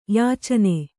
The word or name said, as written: ಯೋಚನ